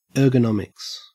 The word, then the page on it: ergonomics: Australian English